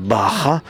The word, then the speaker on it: Baja: Alors ça s'écrit Baja en français, mais ça se prononce à l'espagnol Baja.